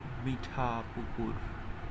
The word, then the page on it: মিঠাপুকুর: ˈmiʈʰaˌpukur